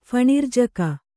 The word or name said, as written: ಫಣಿರ್ಜಕ